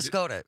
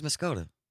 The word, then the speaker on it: Muscoda: Did you say Muscaday by point? – Muscaday. – Apparently... – Oh, did I mispronounce? – Wes from Middleton said not to be that guy, but Muscoda. It was Muscoda.